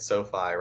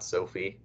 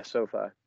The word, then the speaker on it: SoFi: SoFi, right? Not Sophie. – Yeah, SoFi.